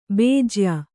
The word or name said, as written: ಭೋಜ್ಯ